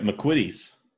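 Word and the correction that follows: McVitie's: And did I say that wrong? How do you say it, Andy? – McVitties. You just did like you did the Pavel pronunciation of the V.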